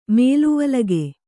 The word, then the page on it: ಮೇಲುವಲಗೆ: mēluvalage